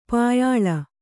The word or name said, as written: ಪ್ಯಾಲೆ